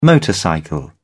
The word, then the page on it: motorcycle: British English